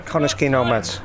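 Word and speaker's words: Connah's Quay Nomads: And I've heard what was the pronunciation again? – Connors Quay Nomads. – Connors Quay Nomads. – Yeah. – Connors Quay Nomads, I got it right.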